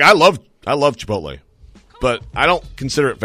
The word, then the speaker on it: Chipotle: Pronounce the L. – Chipotle. – Chipotle.